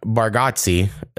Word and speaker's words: Bargatze: And his name is Nate Bargatze, is how I'd pronounce it. But he says his name's pronounced weird, but it's N-A-T-E-B-A-R-G-A-T-Z-E.